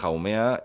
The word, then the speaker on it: Haumea: Se pronuncia Haumea porque decimos los españoles que se pronuncia así, a saber cómo lo dice un americano. – Sí, bueno, decimos Hawái y se escribe igual, o sea, tiene que ser así.